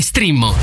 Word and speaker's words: Streammo: Entra adesso nel mondo di Strimmo, vai su strimmo.it, si scrive Streammo o scarica l'app.